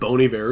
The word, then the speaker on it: Bon Iver: It must have been Bon Iver or something like that.